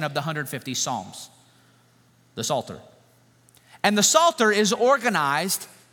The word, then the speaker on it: Psalter: The word is psalter, not like a salt shaker sort of psalter. Okay, you can see the silent P on the front of it.